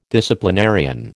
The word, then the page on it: disciplinarian: American English